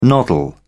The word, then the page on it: noddle: British English